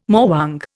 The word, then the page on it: morwong: Indian